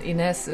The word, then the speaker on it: Inez: Inez Ordner. – Ordner. – Ordner is Austrian, and Inez is my Spanish name.